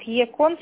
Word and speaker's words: ECON: А есть ECON. Он считается как ЕКОН, собака ETS.Corp.